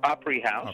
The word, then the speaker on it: Opry House: In the Mickey Mouse short, The Opry House. I don't know if that's how you pronounce it, but that's how I was. – Opry House.